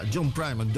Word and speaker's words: John Primer: John Primer, dan... Zeg ik dat goed? Ja, John Primer, Doyle Breblin de second, Kim Wilson en de Fabulous Thunderbirds.